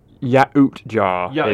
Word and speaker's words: Yautja: We don't see it for It's Yautja. Is phonetically what it Yautja. Is. Yeah. So it's Yautja. – Yautja, I was saying it correct.